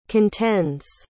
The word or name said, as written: contends